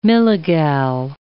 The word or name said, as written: milligal